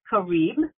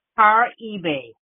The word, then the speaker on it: Caribe: Now, in English, it's called Caribe, and in Spanish, it's pronounced Caribe. And that's just two ways of doing it.